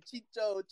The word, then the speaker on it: Chicho: Or it's going to be Chucho, right, if I'm saying it correctly? – Chicho. – Sorry, because I'm used to Chicharito, you know, so Chicho.